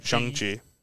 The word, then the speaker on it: Shang-Chi: I recently learned it's pronounced Shang. Shang-Chi.